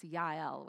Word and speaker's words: Jael: The first one comes from Judges 4, and her name is Jael. Has anyone ever heard of Jael? We have a friend in Switzerland uh, by this name, and I prefer that pronunciation. It's Yael, which I think is more beautiful.